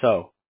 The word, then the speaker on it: Seo: So my last name is spelled S-E-O, but it's pronounced so.